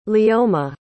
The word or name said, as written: leoma